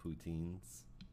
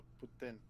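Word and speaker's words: poutine: poutines? – No, Putin. – No, poutines. You're saying it wrong.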